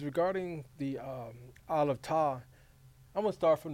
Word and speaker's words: Tav: You know, people, it's spelled T-A-V. Some people pronounce it Tav, but in the Hebrew it's Tav.